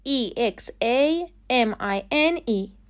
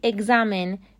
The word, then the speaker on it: examine: فعل اول E X A M I N E به معنی چک کردن یا امتحان کردن تلفظ صحیح examine examine غلطه به